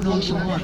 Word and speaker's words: nonchalant: Don't rush, act nonchalant, Amy cautioned. She pronounced nonchalant the French way without sounding the T.